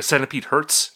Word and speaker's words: Centipede Hz: I mean, they had, Centipede HZ, or Centipede Hertz. Is that how you say it? – That's how I've been saying it.